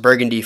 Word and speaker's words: BVRGVNDY: Kyle Smutsky, otherwise known as at, I believe it's pronounced Burgundy Photo, but it's spelled BVRGVNDY Photo on Instagram.